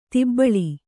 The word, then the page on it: ದಬ್ಬಳ: dabbaḷa